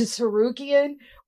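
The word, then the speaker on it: Tsarukyan: You think I'm worried about freaking Armin to Sarukian with a silent T at the beginning of his name?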